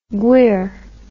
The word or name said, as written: gwyr